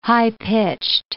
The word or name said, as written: high-pitched